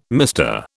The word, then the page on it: MR: British English